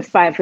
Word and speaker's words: Fyffe: It is Lady G Fife, because there is so much Lady G out there, so I had to put my last name to it. And the Fife is spelled Fyffe.